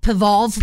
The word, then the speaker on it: P-Volve: It's called P-Volve. – P-Volve? How do you spell that? – P-V-O-L-V-E. Like Volve. P-V-O-L-V-E.